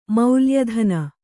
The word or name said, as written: ಮೌಲ್ಯಧನ